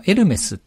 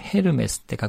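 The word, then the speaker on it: Hermès: 例えばエルメスっていうブランドありますよね。これはヘルメスって書くんですけど、 H を発音しないのでエルメスっていうことで日本でも通じてます。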